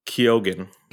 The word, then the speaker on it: Keoghan: Tell us about, and I'm going to probably butcher his last name, Barry Keoghan? – I think it's Keoghan. – Keoghan. – So Keoghan, yeah. Irish name, so we don't really understand that well.